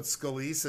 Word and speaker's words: Scalise: But Scalise isn't there. – I know. – Or what's his name? Is that how I pronounce his name? – Yeah, that is how.